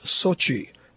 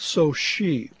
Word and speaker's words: Sochi: Also, it's pronounced Sochi, not So-she, as anyone, not including Brian Williams, should notice the third Cyrillic letter in its name.